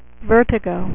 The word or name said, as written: vertigo